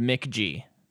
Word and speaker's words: McG: The director of this movie is a guy named Mick G. – But it just said MCG. – Mick G. – Yeah, I know. It just... – That's how you pronounce it, okay? – Okay.